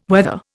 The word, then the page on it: weather: Indian